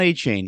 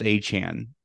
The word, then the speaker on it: Achane: It's not Achane. He said that his name is pronounced Achane, which good for him for telling people.